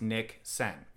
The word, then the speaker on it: niksen: There's a new trend or a newer trend from the Dutch called Niksen, N-I-K-S-E-N, pronounced Niksen.